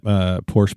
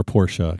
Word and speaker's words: Porsche: It's not Porsche. It's Porsche.